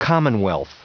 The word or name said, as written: commonwealth